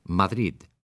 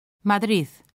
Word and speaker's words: Madrid: Preste atención a cómo pronuncian la D final en estas palabras distintos hablantes. Madrid. – Madrid. – Madrid.